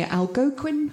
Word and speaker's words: Algonquin: Algonquin Hotel. Is that Algonquin? – How you say it? That's it. Thank you.